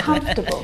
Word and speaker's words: comfortable: I've always wondered about comfortable. – That. – Now, this word, just like vegetable, has an extra vowel in there that we don't need to pronounce. Comfortable. Not comfortable.